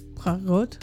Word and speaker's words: Poirot: Poirot.